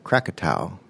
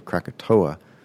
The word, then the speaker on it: Krakatau: And 15 days after the last one died, the Indonesian island of Krakatau, I think that's the correct pronunciation. We sometimes know it as Krakatoa. It's an English pronunciation.